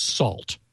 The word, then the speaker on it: NaCl: So he created a library called, it's pronounced SALT, but it's NACL is the way you spell.